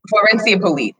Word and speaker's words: Florencia Polite: Florencia Polite.